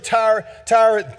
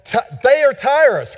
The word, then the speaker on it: Thyatira: So this morning we want to look at the church of Thyatira. I'm pronouncing that wrong. Thyatira.